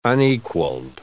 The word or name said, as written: unequalled